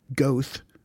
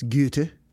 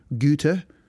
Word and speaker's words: Goethe: because I would have said Goethe, but I think it's Goethe, because he was a German. And so it's Goethe.